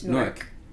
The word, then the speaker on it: Newark: Nobody really Newark. Pronounced the W, I don't think. It's just Newark.